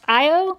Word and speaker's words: Io: So they actually move the fight to the Jupiter moon, and I guess it's pronounced Io.